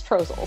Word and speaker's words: Trossel: Because it rhymes with Jostle. – Well, I've hung out with him enough to know it's Trossel, so.